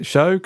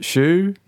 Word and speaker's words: shew: So should we go for pronouncing it shoe? You prefer having show? Because was it at one time pronounced shoe? – Yes, yeah, it was. I mean, it's still occasionally used with that spelling, S-H-E-W, in the modern day, usually in legal documents.